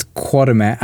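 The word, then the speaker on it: Quatermass: Yeah, and also I think it's Quartermass. I don't know how you pronounce it, but there's no R in there.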